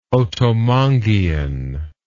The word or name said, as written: otomanguean